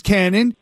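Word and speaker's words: Kannon: Is Cannon. That's spelled with a K.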